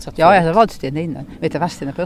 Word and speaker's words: Vadstena: Ja, det är Vadstena. – Det är Vadstena. – Det är rätt. – Vadstena. Man uttalar inte det.